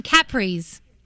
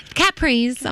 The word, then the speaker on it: capris: She calls them capris. Capris. I'm like, no, that's Capri in Italy. These are capris. That's how you say it, Mom.